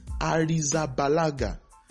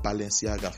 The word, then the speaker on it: Arrizabalaga: It is Arrizabalaga. Kepa Arrizabalaga. Kepa Arrizabalaga. Not Balenciaga, for God's sake, please.